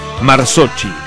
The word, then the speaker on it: Marzocchi: Ernesto Marzocchi, se escribe como Marzo, M-A-R-Z-O-Chi, C-H-I. Marzocchi.